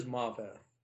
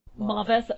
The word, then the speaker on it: Maveth: I did find the correct pronunciation right before we started recording, so it is Maveth. – Maveth.